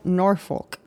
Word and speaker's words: Norfolk: Kelsey Turner was born May 5th, 1993 in Norfolk. How do you say that? – Norfolk, Virginia, to Samantha and Christopher Ingram.